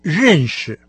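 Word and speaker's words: женьши (认识): Знать кого-то – это не «джидао», как в первом случае, а «женьши». Сначала слог, произносимый с четвертым тоном, а затем с легким.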